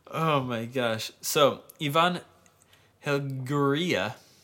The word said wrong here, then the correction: Helguera: That is unconfident pronunciation. – Helguera. – How is it spelled? – H-E-L-G-U-E-R-A. – L-G-U-E-R-A? – Yeah, Helguera.